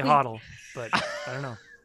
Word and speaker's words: HODL: No, what is HODL's fine. HODL's fine. – You're saying it right.